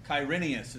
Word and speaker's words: Quirinius: Quirinius is how you pronounce that name, just so you know.